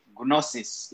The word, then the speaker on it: gnosis: I'm pronouncing it to make the G silent, but you can say gnosis if you want. That's actually the proper way in Greek. It's gnosis. The G has to sound.